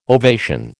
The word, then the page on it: ovation: American English